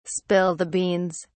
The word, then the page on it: spill the beans: British English